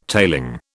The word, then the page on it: tailing: British English